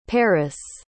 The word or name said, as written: peris